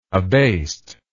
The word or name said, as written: abased